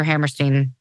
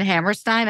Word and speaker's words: Hammerstein: It's just, if I say Rodgers and Hammerstein, I say it correctly. If I look at it, I'm like, that's a Steen. – Doesn't make sense.